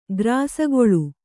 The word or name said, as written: ಗ್ರಾಸಗೊಳ್